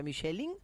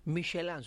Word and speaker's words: Michelin: ¿Cómo se pronuncia? ¿Michelin o Michelin? – Bueno. – Es francés, tiene por el Michelin. – Nada más que Michelin estamos acostumbrados... – Nosotros decimos las Michelin. – Michelin. – La Michelin. – Aquí estamos hablando de Michelin. Pero es Michelin, ella lo pronuncia bien en francés.